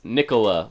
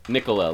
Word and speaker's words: Nicola: Mine is Nicola, like Nickel Cola, but Nicola, like as in Tesla.